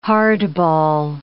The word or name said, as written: hardball